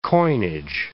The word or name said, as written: coinage